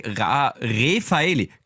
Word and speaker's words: Refaeli: Oder heißt sie Raffaeli? Das weiß ich nicht. – Gleich Refaeli. Gleiches Gebaujahr wie du, Gabriele.